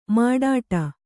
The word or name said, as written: ಮಾಡಾಟ